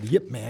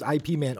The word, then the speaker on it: Ip Man: Nope, it's called Yip Man because that's the guy's name. I found that out that wow. I I thought it was IP Man all this time, and I was mispronouncing it constantly.